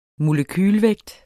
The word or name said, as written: Molekyle